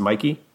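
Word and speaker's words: Michie: Is it Mikey or it's spelled Mitchie, but I think it's pronounced Mikey.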